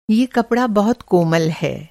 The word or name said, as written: कोमल